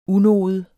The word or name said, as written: unode